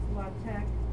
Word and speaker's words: LaTeX: You can also write mathematics if you use the, it's pronounced LaTeX, but it's LATEX syntax,